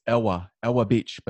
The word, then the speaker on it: Ewa Beach: It's Ewa Beach, but you know, it's the W. – Oh, is that how you pronounce it? – Yeah, Ewa.